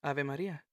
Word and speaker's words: Ave Maria: The way you pronounce it, right, too, Ave Maria. I know how to say it right. You say it right. – I mean, it's Italian. It's not even Spanish. – Well, say it like our people do. – What? How am I supposed to say that? – Ave Maria. – Why do I have to roll the R? – Girl, bye.